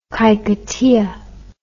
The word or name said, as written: kahikatea